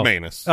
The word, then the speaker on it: McManus: When you have anus, you got to rhyme with it. Yeah. That's what I've always been taught. – And from now on, it is Brandon. – Oh no, McManus.